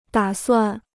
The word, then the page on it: 打算: dǎ suàn